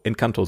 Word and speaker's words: Encanto: Ähm, und da haben wir Ent, en, Encanto, Encanto, so wird's ausgesprochen, Encanto geguckt, der äh, neue Disney-Film oder der aktuelle ah, Disney-Film.